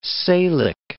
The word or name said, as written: Salique